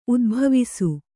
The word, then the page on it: ಉದುಭವಿಸು: udubhavisu